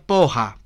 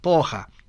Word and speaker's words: porra: porra, que se pronuncia poja, poja.